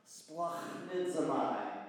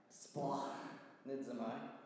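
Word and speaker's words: splachnizomai (σπλαγχνίζομαι): It's pronounced splachnizomai. Splachnizomai. It's a messy sounding word because the root of it is the word for guts.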